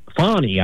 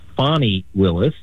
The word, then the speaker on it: Fani Willis: And so what Fannie Fani, I should say. I've been mispronouncing her name, and I do apologize. It's Fani Willis.